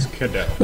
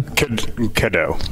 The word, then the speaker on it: Cadeau: And Chris, is it Cadeau? Did I say that correctly? – Cadeau. – Oh, a cadet. How do you say it again? – Cadeau. – Cadeau. Cadeau. Yep. Okay. You're French. – It's tough. It's French.